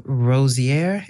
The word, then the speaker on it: Rossier: She was accepted into the Rossier. Is that how you say it? Yes.